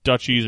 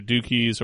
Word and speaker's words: duchies: Which are, yeah, the victory cards, which count, the base game is estates, duchies or dukies or whatever you want to pronounce that, and provinces.